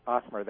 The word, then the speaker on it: Ottmer: Am I pronouncing your last name correctly? – Ottmer, that's correct.